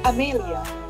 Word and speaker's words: Amelia: Amelia. – Okay. Ih, kayak nama-nama Italia gitu ya? – Jadi orang kayak mempronounce kita nama itu sama dengan Western name like Amelia.